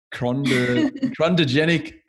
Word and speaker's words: Chondrogenic: I don't even know how to pronounce it. Chondrogenic hydrogel for in situ 3D printing. Is that right? – Yeah, that's correct.